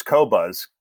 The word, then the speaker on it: Qobuz: But I would say today, I simply bought the service Qobuz, Qobuz. No one pronounces it right because it's from France and it's like they don't use a U after the Q.